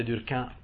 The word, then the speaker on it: Durkheim: Ok, allora Émile Durkheim, dicevamo, Émile Durkheim, che avrebbe voluto che si pronunciasse Durkheim perché era francese e odiava la parte del fatto che il suo cognome fosse tedesco,